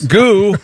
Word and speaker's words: Goux: We're spelling Gou, G-O-U-X. – Is that how you pronounce that? – I would think Gou or Goa. It's not Goux, I can tell you that. Anyway, he won the 1913 Indy 500.